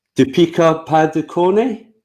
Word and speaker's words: Deepika Padukone: Now, apologies, my wife, if I pronounced this wrong. Deepika Padukone? Nailed it.